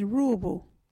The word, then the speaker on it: Ruubu: local Wadjuri word is Ruubu. What is a Ruubu? Ruubu is a word for rope. You can use Ruubu in this sentence. Yeah, get that Ruubu. Ruubu is spelled R-U-U-B-U.